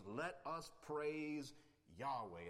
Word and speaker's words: Yahweh: YHWH, which we pronounce Yahweh or Jehovah.